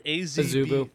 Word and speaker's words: Azubu: Azubu. I hope I'm pronouncing that right. – Azubu. – Yep.